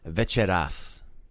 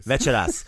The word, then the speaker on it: večeras: So accent the first syllable. Like usual, like Hrvatska, not Hrvatska or Hrvatska, right? It's večeras. Večeras. – Hrvatska coming soon. – True. – Oh jeez. – Večeras.